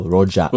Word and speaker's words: Rojak: Pronounce it like a local. Rojak. – Rojak. – Rojak. – Rojak. – Yes, not Rojak.